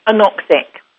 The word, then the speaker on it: anoxic: This, how do you pronounce it? Is it anoxia seizure? – Anoxic.